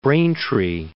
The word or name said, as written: Braintree